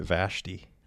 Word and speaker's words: Vashti: I remember it kind of disturbed me that Vashti or Vashti, I will never know how to pronounce that. – Yeah, I've always heard it Vashti.